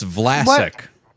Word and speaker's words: Vlasic: But I thought it was Vlastic. – Elastic? – You what? Still said that wrong. It's Vlasic.